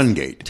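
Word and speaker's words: Toungate: No. What's, Tungate. – Tungate. Yes. But it's spelled Towngate.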